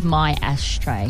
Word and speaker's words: Maestre: And if you're wondering how to say his last name, he explains it. It is my ashtray.